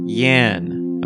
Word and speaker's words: Jan: Janine is a born rebel in the mold of Jan. That's pronounced Yan. Okay, sorry. Janine is a born rebel in the mold of Yan.